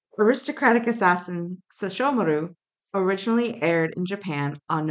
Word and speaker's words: Sesshomaru: You told me that was how to say it. Sesshomaru. – Yeah. – Accent on the show. You said Sesshomaru. – I was just giving you the syllables. Sesshomaru. – That's a very different... That was the key that I was missing. I was like, I know there's a da-da-da-da, but I couldn't figure out where to put the thing.